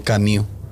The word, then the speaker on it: Camus: Eh, es que hay un, hay un autor que se llama Albert, se escribe Camus, pero es Camus, porque es francés, Albert Camus, escribió un libro que se llama El mito de Sísifo.